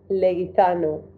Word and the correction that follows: Leguizamo: Leguizamo, no sé si pronuncio bien ese. Ah, no, es, es John Leguizamo, correcto.